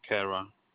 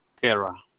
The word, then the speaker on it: Kara: Alexander Kara. The first name, Alexander. – Okay, and your last name? – Kara.